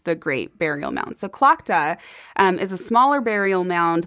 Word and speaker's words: Tlachtga: Her name is spelled T-L-A-C-H-T-G-A. – Oh, yeah. You asked me for the pronunciation of this one. – Yeah, yes. It's Clochta.